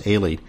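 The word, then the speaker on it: Aly: It's actually, it's spelled Ally. It's A-L-Y, but pronounced Ailey.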